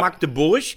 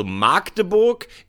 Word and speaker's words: Magdeburg: Magdeburg, du darfst nur das A nicht lang sprechen. Das ist ein Frevel, also Magdeburg ist falsch. Magdeburg oder Magdeburg, das geht beides. – Er schreibt oder Magdeburg.